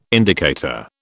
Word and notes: indicator: British English

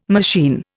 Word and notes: machine: Indian

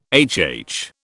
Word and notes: HH: British English

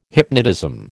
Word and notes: hypnotism: American English

